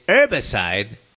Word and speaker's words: herbicide: Glyphosate, the active ingredient in Roundup, the infamous systemic herbicide. Though the more cultured might drop the H and say herbicide, tomato, tomato, no matter.